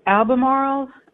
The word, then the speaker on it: Albemarle: So now tell me, how do you say it, Albemarle or Albemarle? – It's pronounced Albemarle, but I actually live in Raleigh, North Carolina.